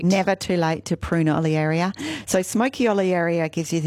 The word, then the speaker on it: Olearia: I have some stunning little smoky olearia. Is that how you pronounce it? – Olearia. Yeah, that's okay. Depends what school you went to, but I don't mind.